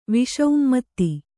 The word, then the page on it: ವಿಷಉಮ್ಮತ್ತಿ: Kannada